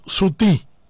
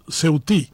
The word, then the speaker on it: ceutí: Por lo tanto, se dice limón ceutí. Y nosotros pronunciamos en una versión en guaraní, sutí, de forma nasal. Algunos llaman sutil.